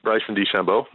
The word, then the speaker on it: DeChambeau: What do you know about the other amateur, Beauchamp? I'm saying it wrong. – Bryson DeChambeau. – DeChambeau, yeah.